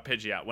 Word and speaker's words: Pidgeot: Is that the right way to say that, too? Because that's what I've always... It is, okay. Yeah. – Fun fact. I want to say one thing about Pidgeot.